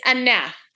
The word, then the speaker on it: Aneth: It's Aneth. It's like Annette, but it has a TH.